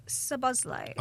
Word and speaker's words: Szoboszlai: Well, Dominic Szoboszlai. – Oh, very well pronounced.